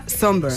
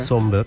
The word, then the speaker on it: Sombr: Pues mira, yo busqué cómo se pronunciaba por si acaso, pero efectivamente se pronuncia Somber. – Somber, sí. – ¿Y se escribe? Somber, Somber, Somber. Sí, sí, sí. Exacto. – Y se escribe S-O-M-B-R, por si la gente lo quiere buscar.